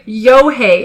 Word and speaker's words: Eochaidh: So the lake was formed when her father, Yohei, by the way, Yohei is spelled E-O-C-H-A-I-D-H. Like I said, thank goodness I found a pronunciation guide here. – It might just be pronounced Sean. – So Yohei is a son of a king of Munster and one of the Tuatha De Danann.